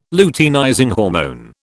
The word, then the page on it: luteinizing hormone: British English